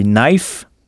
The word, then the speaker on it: knife: Et si un couteau se dit knife, comment prononcera-t-on son pluriel? – Knives. – Knives, oui. Et sachez que knife s'écrit K-N-I-F-E, mais on ne prononce pas le K initial.